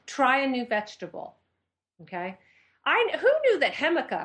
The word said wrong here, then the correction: jicama: am I saying that right? Hemica. How do you say that? – Jicama? – Jicama. I say, loser.